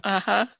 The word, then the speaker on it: Jawas: And it was so funny that one of the guys used his sock for the Jawas. Am I saying that right? – Yes. Jawas.